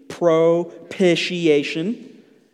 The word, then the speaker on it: propitiation: You might look at this verse and, you know, mispronounce the big P word, propitiation. Say it with me, propitiation.